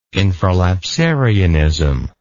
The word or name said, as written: infralapsarianism